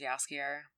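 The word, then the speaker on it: Jaskier: Who plays, I think it's pronounced Yaskier. – Yaskier? – Yeah. Yaskier.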